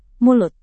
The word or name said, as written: mulut